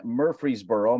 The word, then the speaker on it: Murfreesboro: But this event is now behind you as you reflect back on the 3 days in Murfreesboro. Am I saying that right, Ronnie? – You got it.